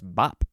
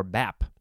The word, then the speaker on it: bap: I guess it's just pronounced bop or bap. It is spelled B-A-P.